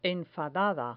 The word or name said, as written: enfadada